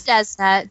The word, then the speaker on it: Desna: It's Desna.